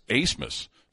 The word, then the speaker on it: Abmas: And so, well, let me check their pronunciation guide, and it's spelled A-B-M-A-S, but it's pronounced Asmus.